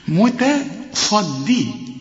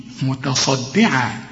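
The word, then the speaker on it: متصدعا: انا عاوزك تكون دقيق قوي في نطق هذه الكلمة لان عندك صاد وبعدين عندك دال. الدال هتضيع منك اذا ما طلعتهاش دال خالص، هتطلع ضاد. قل لي متى، ما تقولش متى غلط، متصدي غلط، متصدي متصدعة من خشية الله.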